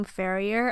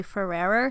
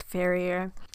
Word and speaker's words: Ferrier: My name is Emily Ferrier. That is my last name, Ferrier. A lot of people spell it and say completely wrong. They say Ferrerer somehow or Ferrier. It's just Ferrier now that we've got that cleared up, I am 23 and I was born in December, so I am a Sagittarius.